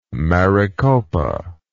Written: maricopa

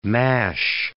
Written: mash

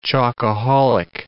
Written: chocoholic

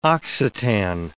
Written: Occitan

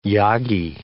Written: Yagi

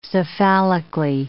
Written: cephalically

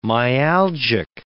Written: myalgic

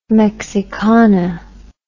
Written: Mexicana

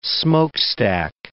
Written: smokestack

